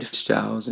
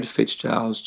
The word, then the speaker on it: Fistzgiles: So right now, they can find me on Twitter at Fred Fistzgiles. And my last name is spelled F-I-S as in Sam, T as in Tom, Z as in Zebra, G-I-L-E-S.